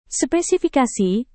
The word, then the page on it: spesifikasi: Indonesian